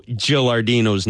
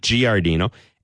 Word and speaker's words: Gilardino: Secondly, thank you for finally telling me how the hell to pronounce Alberto Gilardino's name. – There you go. Perfect. – Perfect. It's not Giardino.